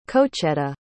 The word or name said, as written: cocheta